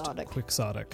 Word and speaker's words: quixotic: But in English, it is actually pronounced quixotic. – Yes. Don Quixote, we do tend to say in English, but... It is quixotic.